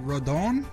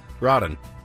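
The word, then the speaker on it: Rodón: I heard Carlos Rodon. – Rodon. – There's an accent on the second O.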